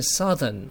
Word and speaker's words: southern: 南这个字你常常念错，南是念 south，可是形容词它改变的发音，为什么会念得比较顺？它念 southern，所以 southern。